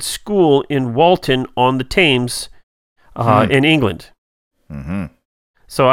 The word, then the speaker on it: Thames: I mean, it's Thames, but whatever. – Thames, you know. See, I don't do foreign languages very well. – Well, this one isn't. – It's quite close enough. – Anyway. – We speak American and they speak they speak. I barely do my language. – I'm not going to bicker and argue about how an A should be pronounced, especially when followed by an M. – Yeah. – Like, you know, but apparently it's with an E sound.